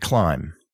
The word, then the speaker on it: climb: So again, thumb, T-H-U-M-B, comb, which is es un peine, plumber, un plomero, and climb, o subir, trepar. So the B in these words is silent for some reason. And someone reading these words may say thumb or comb, and that's not what we say.